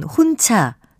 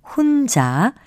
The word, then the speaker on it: 혼자: 지금 들으신 예문에 나온 혼차 숨켜는 모두 주위의 음성적인 환경을 봐도 거센 소리로 발음될 이유가 전혀 없는 말들입니다. 이것은 글자 그대로 혼자 그리고 숨겨로 발음하는 것이 맞습니다.